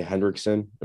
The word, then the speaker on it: Hendrickson: And then they have Trey Hendrickson.